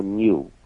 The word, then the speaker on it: GNU: GNU o en castellano, new. – New. – Se puede pronunciar. – New en castellano. – GNU es demasiado trabajo, nunca lo diría yo.